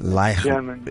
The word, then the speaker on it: Raichlin: Is it Raichlin? – It's pronounced Raichlin. – Raichlin.